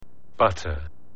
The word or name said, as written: butter